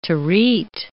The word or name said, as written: terete